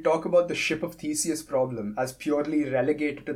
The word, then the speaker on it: Theseus: It sounds like a Ship of Theseus thing. I don't know how to pronounce it. Theseus? – Theseus.